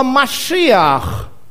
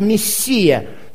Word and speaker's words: Машиах: А в греческом языке, когда они переводили, то в греческом языке нету звука «ше». Поэтому Иисус вместо «Иешуа» Мессия вместо Машиах, вот, тоже греческое не имеет произносить вот эти концевые «х», поэтому разница в произношении.